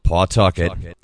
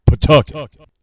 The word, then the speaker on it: Pawtucket: If you hear someone pronounce it the way it's spelled, Pawtucket, you know that person's an out-of-stater. Here in Rhode Island, it's Pawtucket.